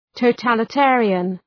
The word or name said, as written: totalitarian